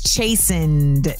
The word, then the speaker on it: chastened: Chasten. – That is the wrong pronunciation, but it is the correct word. The word is chastened. Chastened. It does look like Chasten.